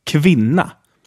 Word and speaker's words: kvinna: And finally, K in front of a consonant. Kvinna, which means woman. Kvinna. When K is in front of other soft vowels, it is pronounced like sh, like in sheet or show in English.